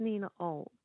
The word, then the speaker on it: Nina Aouilk: It's actually pronounced Nina Aouilk. – Aouilk. – Nothing like it's spelled, but it's got every vowel except the E.